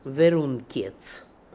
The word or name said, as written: Veroonkietz